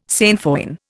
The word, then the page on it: sainfoin: Indian